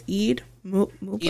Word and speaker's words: Eid Mubarak: I think it's Eid Mubarak. Eid Mubarak.